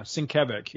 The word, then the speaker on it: Sienkiewicz: Because I mispronounced Sienkiewicz for the longest time.